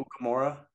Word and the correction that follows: Nakamura: Is that how you pronounce his name? – Who? All. – Yeah. – I'm Tennessee? – Yeah. – Nakamura. – No, I thought it was like a Nukamura or something.